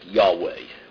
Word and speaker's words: Yahweh: The term Jehovah is an old word used in some English translations to represent the Hebrew tetragrammaton, Yod, He, Vav, He, now thought to have been pronounced Yahweh.